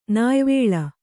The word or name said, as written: ನ್ಯಾವಳ